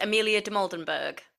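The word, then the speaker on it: Amelia Dimoldenberg: I think, and I'm going to butcher the pronunciation of this now, but is it Amelia de Moldenberg? – Yeah, well, it's not butchering.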